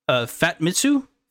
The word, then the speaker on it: Famitsu: Famitsu.